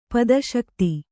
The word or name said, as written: ಪದಶಕ್ತಿ